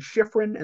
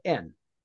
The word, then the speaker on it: Shifrin: My full name is Austin Shifrin, and that last name is spelled Shifrin.